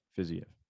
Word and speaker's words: Fiziev: Co-main, Brad Riddell and Rafael Fiziev, or however you say his name. – Fiziev.